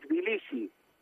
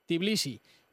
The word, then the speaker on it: Tbilisi: Estamos en Tiflis o Tbilisi. ¿Cómo se dice en castellano mejor? – En Tbilisi. – Tbilisi. Y tú eres el... se llama Tbilisi.